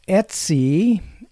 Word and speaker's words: etc: And slash Etsy, ETC, which mm-hmm. most people tend to pronounce Etsy, is one of those directories or folders that is a system type directory, and it contains etc.